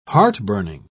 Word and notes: heartburning: British English

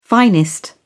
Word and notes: finest: British English

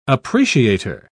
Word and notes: appreciator: British English

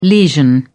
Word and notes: lesion: British English

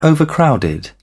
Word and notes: overcrowded: British English

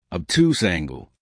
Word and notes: obtuse angle: American English

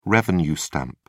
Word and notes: revenue stamp: British English

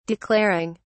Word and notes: declaring: British English

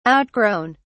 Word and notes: outgrown: British English

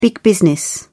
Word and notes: big business: British English

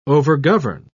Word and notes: overgovern: British English

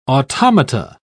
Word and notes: automata: British English

